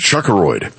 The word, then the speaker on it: Chuckeroid: Apparently up next is asteroid 14332, which will be named Chuckeroid after the king of all traffic, Chuck Whitaker. – Just make it an asteroid. – That's right. Emphasis on the first syllable.